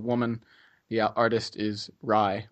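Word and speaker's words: Rhye: Is I believe the artist's name is pronounced Rye. It's R-H-Y-E.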